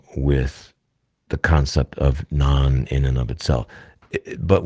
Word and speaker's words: Gnon: So they talk about the concept of non. It's spelled G-N-O-N with a silent G, which is an acronym for the God of nature or nature's law.